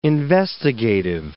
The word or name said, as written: investigative